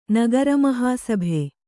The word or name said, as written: ನಗರಮಹಾಸಭೆ